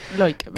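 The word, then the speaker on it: Loïc: Pues vamos a empezar con la primera tesis que defiende este hombre, Loic, o ¿cómo se pronuncia? Voy a decir Loic. – Loic.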